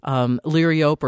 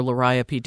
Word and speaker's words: Liriope: I mean, there is Liriope or Liriope, depending on how you like to pronounce it, that should be hardy for Platteville.